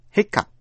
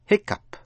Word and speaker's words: hiccup: Một chữ cần để ý là hiccup, hiccup. Quý vị vừa học chữ cough là ho, nhưng chữ hiccup nghĩa là nấc cụt, nấc. Chữ O phát âm là ơ và GH phát âm như chữ P, cũng viết tắt là H-I-C-C-U-P.